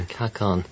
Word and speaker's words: kakon: Plato used the term, how do you pronounce it, K-A-K-O-N? – Kakon.